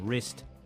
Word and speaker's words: wrist: Wrong and wrist both begin with a silent W.